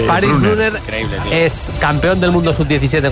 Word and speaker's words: Brunner: Paris Brunner, delantero centro alemán, juega en el Mónaco.